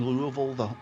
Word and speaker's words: Louisville: Am I pronouncing Louisville the right way? Is that... – You're doing great.